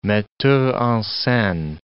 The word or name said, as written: metteur en scene